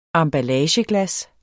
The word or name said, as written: emballageglas